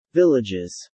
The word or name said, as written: villages